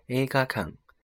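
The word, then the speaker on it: 映画館: 那映画读作 a 嘎 a 嘎，那由此延伸，电影院就被称为是映画馆，读作 a 嘎康 a 嘎康。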